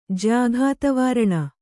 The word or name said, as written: ಜ್ಯಾಘಾತವಾರಣ